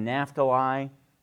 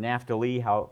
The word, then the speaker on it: Naphtali: Bilhah has Dan and Naphtali, or Naphtali, depending on how you want to pronounce it, and Zilpah has Gad and Asher.